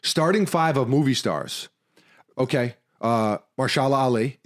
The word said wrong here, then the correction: Mahershala: What did I say? How do you pronounce it? – Mahershala, I think. – Mahershala.